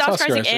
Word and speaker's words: Oscar Isaac: It's Oscar Isaac and Oscar Isaac, and maybe hopefully Oscar Isaac, and then Ethan Hawke is there.